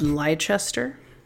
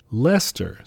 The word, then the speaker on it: Leicester: Leicester. Sorry. – Leicester? – That said Leicester. Like the name Leicester. – It's got an extra like syllable in there. – L-E-I-C-E-S-T-E-R.